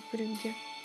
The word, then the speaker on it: Брюгге: Брюгге ведь произносится. Да, Брюгге.